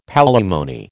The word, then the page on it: palimony: American English